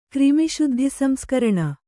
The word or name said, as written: ಕ್ರಿಮಿಶುದ್ಧಿಸಂಸ್ಕರಣ